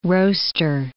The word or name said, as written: roaster